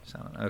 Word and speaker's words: Samhain: Or Samhain. He pronounced it correctly. He pronounced it Samhain. – Yes. Samhain.